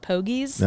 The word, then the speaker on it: Pogies: Um, I think that Coach Lindsay would just say it's the same way you spell pogs, but you pronounce it pogies.